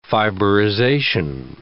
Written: fiberization

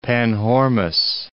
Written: Panhormus

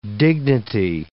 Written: dignity

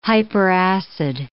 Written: hyperacid

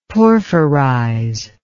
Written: porphyrize

